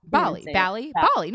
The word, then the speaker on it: Bali: Bali? I said it wrong. – Bali. No, it's Bali.